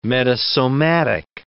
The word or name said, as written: metasomatic